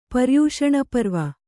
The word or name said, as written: ಪರ್ಯೂಷಣಪರ್ವ